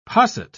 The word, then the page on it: posset: British English